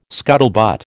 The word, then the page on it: scuttlebutt: American English